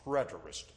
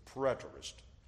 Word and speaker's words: preterist: The preterist view. The preterist. You spell it P-R-E-T-E-R-I-S-T. Preterist, which comes from Latin, which means past. Preterist. P P-R-E-T-E-R-I-S-T.